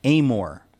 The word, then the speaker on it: Amor: He's right here in the studio, and it is pronounced Amor.